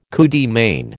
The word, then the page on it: coup de main: American English